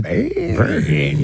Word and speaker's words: Bain: I think that Bain. – Yeah. – You have to say his name like that.